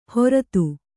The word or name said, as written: ಹೊರ್ತು